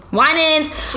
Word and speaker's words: Winans: C.C. Wyans. Did I say that right? – Winans. – I'm sorry, great. Girl. She's really great. CC Winans, if you're listening, I got it right.